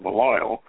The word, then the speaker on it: Belial: along with the archdemon Belial, some people pronounce that Belial, who is the disembodied villain of Piper's tale.